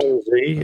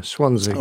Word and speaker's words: Swansea: More of a Z sound? – Swansea, yes. Pretend there's a Z in there. Swansea.